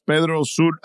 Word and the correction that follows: Pedro Sula: Honduras, right? – Pedro Sula, yeah. – Pedro Sula, thank you. And the reason I'm making sure I pronounce that correctly is that is the most dangerous city in the world.